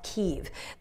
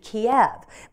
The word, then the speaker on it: Kyiv: The common pronunciation used to be Kiev, but that's based on the Russian pronunciation of that city.